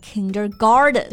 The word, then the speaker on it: kindergarten: 嗯、那幼儿园，a kindergarten。 K I N D E R G A R T E N. A kindergarten is a school or class for children aged 4 to 6 years old. It prepares them to go into the first grade. – Right, a kindergarten. 这个单词比较长，大家注意一下发音，就是它的重音呢是在第一个音节上，kindergarten，不要读成了 kindergarten。